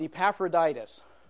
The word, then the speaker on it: Epaphroditus: It's pronounced Epaphroditus.